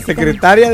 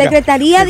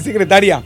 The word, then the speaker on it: secretaría: A la Secretaría de Educación Pública. – Secretaría. Dije secretaría. – Ah, te entendí, secretaria. – No, secretaría. A lo mejor le faltó el acento. – El acento en la I.